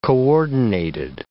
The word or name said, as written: coordinated